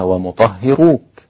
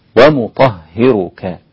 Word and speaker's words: ومطهرك: ومطهرك ما ينفعش أقولها ومطهروك، يعني ربنا الذي يقول الكلام بعيسى عليه السلام ومطهرك، يبقى لازم أخد بالي من الكلمة ومطهرك وأفضل أعيدها عشان أتكلم بها وأنطقها صحيحة. هكذا ومطهرك. تمام.